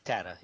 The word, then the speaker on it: Tata: Tata, yeah. – Tata, okay. So it's just like the English pronunciation.